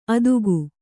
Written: ಅದುಗು

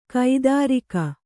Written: ಕಾತರಿಕೆ

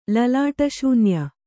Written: ಲಲಾಟಶೂನ್ಯ

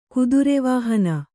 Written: ಕುದುರೆವಾಹನ

